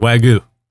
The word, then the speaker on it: Wagyu: And you say Wagyu. – Yeah. – Like W-A-I-G-O-O. Wagyu. – And Wagyu. – You've committed. No, stop it. That's not how you say it. You say Wagyu. – Wagyu.